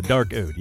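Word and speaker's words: Darkode: I think that, Dark Ode. Yeah, that's how it's said.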